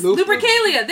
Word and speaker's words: Lupercalia: Yes, Lupercalia. Thank you. I didn't know how to say it. Lupercalia.